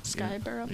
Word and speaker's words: Scarborough: Am I saying that wrong? – Skyborough. Yeah, that's right. – It's really written Scar and then Borough, though.